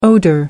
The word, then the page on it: odour: American English